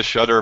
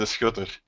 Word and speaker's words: Schutter: Well, in English, I typically just say the Shutter, but it's actually the Schutter.